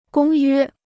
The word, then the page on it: 公约: gōng yuē